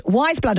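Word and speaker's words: Weyes Blood: Just Wise Blood, and of course that's spelled W-E-Y-E-S, Blood.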